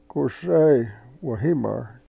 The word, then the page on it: ku sey we-himar: Yurok